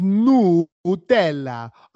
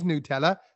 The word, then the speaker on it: Nutella: The correct pronunciation is Nutella. A Nutella. It's Italian. So actually, it is Nutella.